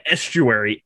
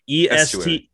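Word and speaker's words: Esteury: Estuary. Estuary. Yeah, like the water thing. – Oh. – I don't think it's spelled the exact same way, but Estuary.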